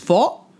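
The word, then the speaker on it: phở: It's pronounced faux.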